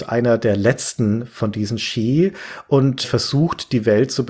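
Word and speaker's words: Sídhe: Xi spricht man das aus. – Xi spricht man das aus? – Ja, das ist ja ein Originalname aus der keltischen Mythologie.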